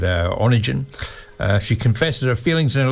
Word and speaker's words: Onegin: It's Onegin, it's pronounced, I think.